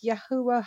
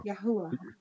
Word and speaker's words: Yahuwah: You're like Malak, you said it wrong. It's Yahuwah Hawa. Yahuwah.